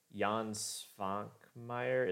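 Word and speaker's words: Švankmajer: I will say the one animator that this reminds me of is the work of Jan Svankmajer. Is that how you say his name? – Yes.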